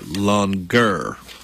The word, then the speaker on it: longueur: L-O-N-G-U-E-U-R. Longer. – Well, all right. – That's how it's pronounced.